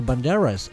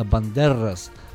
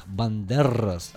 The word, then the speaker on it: Бандерас: Не Бандерас, а Бандерас. Как произносится рр, прям ракают Бандерас.